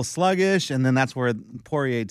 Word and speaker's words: Poirier: No, but Poirier, how do you pronounce Poirier? – Poirier.